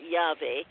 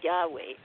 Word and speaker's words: Yahweh: It was just consonants, and people actually are not entirely sure how it's pronounced, but people have settled on Yahweh, YHWH, and it's called the Tetragrammaton, which in Greek means four letters. So YHWH, Yahweh.